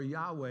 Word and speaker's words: Yahweh: God was gracious enough to answer, and the name he gave is recorded in the original Hebrew as YHWH, or Yahweh is the way we say it. We kind of add a couple of vowels in there in order to pronounce it.